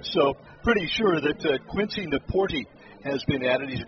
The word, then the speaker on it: Nieporte: Quincy Napoorty, last name is N-I-E-P-O-R-T-E. Napoorty is how it's pronounced, and he has joined the ball club.